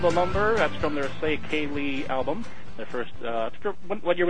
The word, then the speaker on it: Ceili: I like how the first album out of the gate, you taught people how to pronounce the name properly. – We tried. – It's called Say Kaylee.